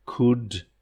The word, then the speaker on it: could: I could, and that ULD at the end, could, like the sound would, yeah?